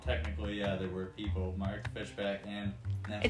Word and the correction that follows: Fishbach: It's Fishbach, actually. Not Fishback.